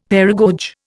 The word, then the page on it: paragoge: Indian